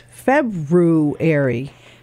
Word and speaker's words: February: How do you say it? – February. – See, you say, did you say February? – Uh-huh. I didn't say the R.